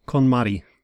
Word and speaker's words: KonMari: KonMari.